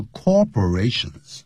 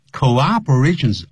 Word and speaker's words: cooperation: Cooperations， C-O-O-P-E-R-A-T-I-O-N，所以一定要念成 co 再再来一个 operation。可是我们乘务员呢或者一些呢就是机长呢常会念 Thank you for your cooperation，不是 corporation，这叫 cooperation。如果你念成 corporation 代表公司了啊，那就是特别要记住。